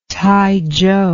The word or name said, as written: taizhou